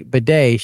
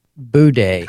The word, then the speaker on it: bidet: She says, and next to the regular toilet is a bidet, except she didn't say bidet. She pronounced it Boudé. Boudé.